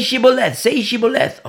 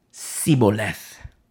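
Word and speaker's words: Shibboleth: And, you know, say Shiboleth. Okay, here we go. Siboleth. You see? But in verse 6, for he could not pronounce it right.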